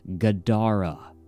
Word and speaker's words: Gadara: Joe Shackleman said, as the creature and Shackleman slowly backed away from each other, the monster made a three-syllable growling noise that sounded like Gadara, with the emphasis on the second syllable.